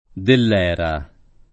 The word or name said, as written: Dellera